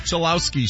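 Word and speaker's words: Cholowsky: Took Rock Chalowski, shortstop from UCLA. – Hey, you just pronounced that correctly.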